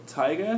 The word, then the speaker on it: Taiga: Taiga, is that correct? – That is correct. – Did I pronounce it right? Yeah. – Taiga.